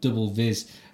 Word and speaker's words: DBLVIS: It's by an artist called Ixtab, Ixtab, and this is a track called Dvis, presumably Double Vision, but it's spelled DBLVIS. Anyway, some gnarly techno. Listen to this. – Thank you. – So that's the sounds of Ixtab. The track is called Double Viz.